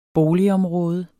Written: boligområde

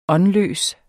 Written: åndløs

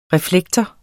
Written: reflektor

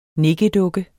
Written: nikkedukke

